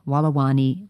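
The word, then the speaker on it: Walawani: This is by Toby Hemmings, and it's titled Natural Burials Begin at New South Wales Conservation Cemetery, Wallawani Way. I'll spell that for you. It's W-A-L-A-W-A-N-I, Wallawani Way.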